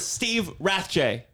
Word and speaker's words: Steve Rathje: We're here with Steve Rathjay.